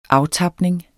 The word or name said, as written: aftapning